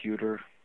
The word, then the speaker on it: Huter: My name is Dr. Bob Huter. – It's pronounced Huter. Rhymes with computer, I guess.